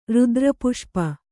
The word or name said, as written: ರುದ್ರಪುಷ್ಪ